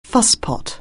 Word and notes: fusspot: anglais

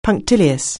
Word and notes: punctilious: anglais

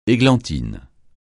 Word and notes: églantine: francais

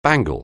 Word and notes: bangle: anglais

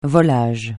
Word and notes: volage: francais

